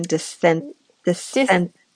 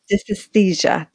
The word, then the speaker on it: dysesthesia: Dysesthesia. – Dysesthesia.